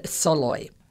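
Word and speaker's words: Szalay: David Soloy, but that's not phonetically how it's spelled. So it's S-Z-A-L-A-Y.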